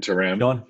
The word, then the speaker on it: Shantaram: Shantaram. – Shantaram.